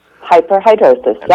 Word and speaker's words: hyperhidrosis: Is that, did I pronounce that properly? – Hyperhidrosis, yep.